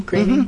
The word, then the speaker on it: Groening: It is Matt Groening's new series. Is that the way you pronounce his name, Groening? – Mm-hmm. Yeah, Groening. Yeah.